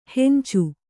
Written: ಹಂಚು

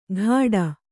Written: ಘಾಡ

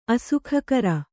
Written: ಅಸುಖಕರ

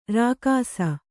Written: ರೇಗಿಸು

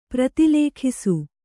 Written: ಪ್ರತಿಲೇಖಿಸು

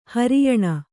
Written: ಹೆರೆಯೆಣ್ಣೆ

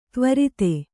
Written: ತ್ವರಿತ

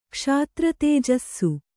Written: ಕ್ಷಾತ್ರತೇಜಸ್ಸು

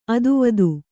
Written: ಅತಿವಾದಿ

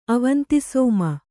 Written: ಅವಂತಿಸೋಮ

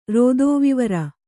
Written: ರೋದೋವಿವರ

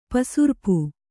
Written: ಪೆಸರ್ಪು

